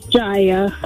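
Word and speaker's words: Jaia: No, it's J-A-I-A is what Griff wrote. – Hello? – Jaya, am I saying your name correctly? Is it Jaya? – It's Jaya.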